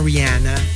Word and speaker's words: Rihanna: Rihanna, Rihanna, which is actually the correct pronunciation.